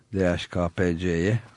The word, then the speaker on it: DHKPC: Bir yandan da Türk hükümetinde hata görmüyoruz ama kanıtlar toplanınca DHKPC'ye, öyle okunuyor değil mi o? – Evet, DHKPC. – Niye o ha diye okumuyoruz bilmiyorum ama öyle yerleşmiş dile.